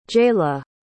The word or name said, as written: jalie